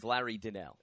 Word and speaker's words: Donnell: How are we pronouncing his last name? – I've always went with Larry Donnell.